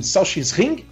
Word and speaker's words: Sachsenring: Teve o Grande Prêmio da Alemanha, né, em Sachsenring, né, se eu não me engano, a pronúncia é esta, tá?